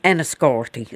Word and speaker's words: Enniscorthy: Mary Jevons from Enniscorthy.